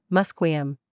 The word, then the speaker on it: Musqueam: Even the spelling of the name Musqueam is an old anglicization that the community is gradually beginning to phase out. Unlike English, where characters often stand in for a variety of different sounds, the current alphabet accurately represents the historical pronunciation, Musqueam.